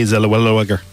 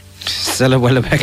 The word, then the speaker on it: Zellweger: Also, you pronounce it Renee Zellweger. – Zellweger.